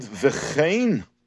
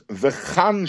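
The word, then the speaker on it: vechein (וְכֵן): So the Rebbe says that the word vechein we pronounce with the vowel of tzeirei, vechein, and not bepasach with the vowel of pasach, which is vechan shnei k'suvim.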